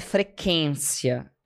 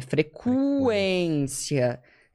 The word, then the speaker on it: frequência: Então, não é frequência, é frequência, é sequestro.